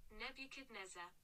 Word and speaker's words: Nebuchadnezzar: Nebuchadnezzar. Nebuchadnezzar. – Nebuchadnezzar. – Oh no, spelling it isn't going to help, but hang on. I'm just going to see how Google tells us to pronounce this. Nebuchadnezzar.